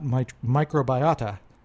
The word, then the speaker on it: microbiota: as well as her investigation of the gut microbiota. Am I pronouncing that correctly? – Yes.